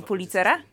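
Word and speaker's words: Pulitzer: Y, nie przez przypadek wspomniałem o nagrodzie Pulcitera. W, w, w, A nie policera? – Policera. przepraszam, tak, oczywiście, że nagrodę policera.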